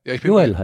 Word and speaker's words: Joel: Joel. Joel heißt der. Der heißt nicht Joel.